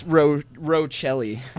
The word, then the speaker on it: Rochelle: And is it Rachel or Rochelle? – It's Rochelle. – Rochelle. – Phonetically speaking.